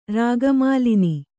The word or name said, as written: ರಾಗಮಾಲಿನಿ